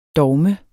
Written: dogme

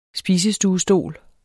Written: spisestuestol

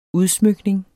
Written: UDSMYKNINGERNES

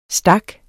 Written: stak

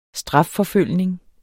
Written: strafforfølgning

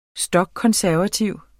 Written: stokkonservativ

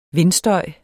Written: vindstøj

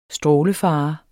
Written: strålefare

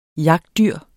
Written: jagtdyr

